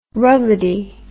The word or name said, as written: rubbidy